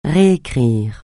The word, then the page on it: réécrire: francais